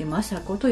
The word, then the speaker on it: 和子: 政子というのは平和の和、和子と書いて政子と読みます。この東福門院政子のお母さんにあたる方は来年の大河ドラマ、主人公のね、浅井三姉妹の末娘のお郷さんなんですね。お郷の娘であるこの東福門院政子